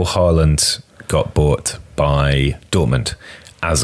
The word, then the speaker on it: Haaland: No, it's just pronounced Haaland.